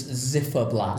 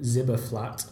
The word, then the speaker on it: Ziferblat: So, we've just recorded the very first El Food Brothers podcast in the lovely surroundings of, and now I keep pronouncing this wrong, I believe it's Zifferblatt. – Zifferblatt. Now, I ended up calling it Zibberflat.